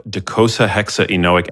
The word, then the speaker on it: docosahexaenoic: I actually like this question because caviar provides a form of docosahexaenoic acid, aka DHA fat, which is one of the most...